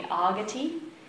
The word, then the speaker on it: Agati: So Sharana means shelter, and Agati, that's the long A, Agati, means coming forth, coming towards.